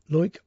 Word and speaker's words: Loïc: The two lads are called, and I'm going to get this wrong, I realise now, I pronounced it Loic before. It's L-O-I-C, which I think is Loic Bléjean,